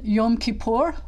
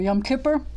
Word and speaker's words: Yom Kippur: Then Yom Kippur, which is sometimes called Yom HaKippurim, or Yom Kippur in the Ashkenazic pronunciation.